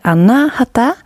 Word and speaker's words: Anahata: Och jag tycker att det är lite roligt för jag har lärt mig att man ska uttala det Anahata och då kan man tänka att det låter som hjärtslagen. Anahata, Anahata, Anahata.